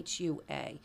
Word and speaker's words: Chua: So I was going to recommend The Golden Gate by Amy, and I don't want to mispronounce her name. Chua, it's Chua.